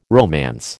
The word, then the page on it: romance: American English